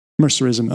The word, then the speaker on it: Mercerism: Dissent isn't overtly criminalized, but questioning mercenarism is taboo. How do you say that? Mercerism.